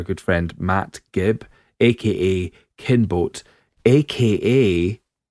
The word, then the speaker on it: Kinbote: It sounds like, no offence to the actual pronunciation, Kinboat, but Kinbote.